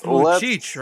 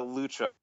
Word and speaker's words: Luchuk: All right, that's not pronounced Luchik, right? – No, it's a Luchuk.